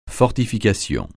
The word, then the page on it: fortification: francais